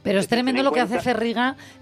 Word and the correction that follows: Ferrega: eh, eh, Ferrega, ¿no? Porque siempre me lío con cómo pronunciarlo, Ferrega. Yo la conocí hace unos años que, bueno, estaba, no me acuerdo, es que no me acuerdo bien dónde estaba.